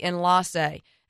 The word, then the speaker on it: Enlace: Kind of exclusive, a little bit hard to find, but the bottle says Enlace, Enlace. That's how it's spelled. It's actually Enlace, and it means interlaced, and surely these flavors were great.